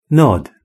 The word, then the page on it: nod: British English